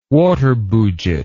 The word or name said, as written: waterbouget